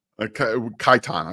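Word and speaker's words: Kitan: Let's start with, I'm so sorry, I'm going to butcher this, Keaton Oladapo? – Kaitan, I believe.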